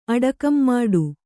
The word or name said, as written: ಅಡಕಂಮಾಡು